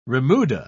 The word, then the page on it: remuda: British English